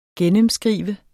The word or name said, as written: gennemskrive